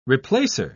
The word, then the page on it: replacer: British English